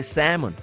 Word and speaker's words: salmon: Cuando decimos salmón, se escribe salmón, pero se dice salmon.